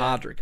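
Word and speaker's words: Pádraic: Is it Padrick? Mm. They make it sound like it's There's Podrick. a couple of different pronunciations. Podrick. I think it sounds like Podrick, but it's yeah. Padrick apparently.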